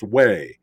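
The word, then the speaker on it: Huế: And, there was a town of, it was spelled Hugh, but it's pronounced Way.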